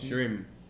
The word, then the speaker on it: shrimp: Sí, no se dice así, chip. – Shrimp. ¿Cómo se pronuncia eso? ¿Camarón en inglés? S-H-I-R-P.